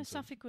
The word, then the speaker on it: Suffolk: In the glove department, there's a handwritten letter addressed to the Suffolk County Police Department. I'm really bad with American pronunciation. – No, Suffolk was right.